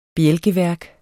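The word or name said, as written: bjælkelag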